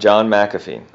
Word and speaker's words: McAfee: McAfee? – Yeah, John McAfee.